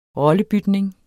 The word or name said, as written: ROLLEBYTNING